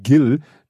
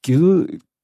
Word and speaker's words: Gil: Ich habe den Gil Oferim wieder Jill ausgesprochen, weil ich finde dieses, ich finde einfach dieses Wort oder diesen Namen Gil, da, da sträubt sich in mir was.